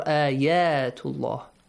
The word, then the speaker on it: Ayatullah: Yeah, good job with that light sound. An a sound. Open your mouth to the left and right, right? Horizontally. – Yes. – Right? So, not ayatullah. Ayatullah. Ayatullah. To Allah, right? – Yes.